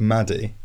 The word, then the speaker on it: Matty: It's spelt Matty, but it's pronounced Maddie.